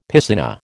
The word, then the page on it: piscina: American English